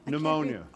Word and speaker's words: pneumonia: The word from Maine is, Felimonia? – Pneumonia. – Pneumonia. I couldn't read it.